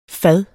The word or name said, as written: fad